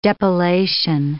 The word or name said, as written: depilation